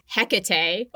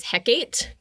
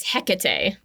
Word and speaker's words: Hecate: So the correct pronunciation is Hecate. – Oh, okay. – But I always read it as Hecate because it's H-E-K-A-T-E. – Yes. – But it's Hecate, and it's the goddess with three faces.